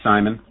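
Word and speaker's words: Syman: The name is Simon, and it's usually spelled Sierra India Mike Oscar November.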